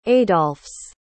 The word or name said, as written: adolphs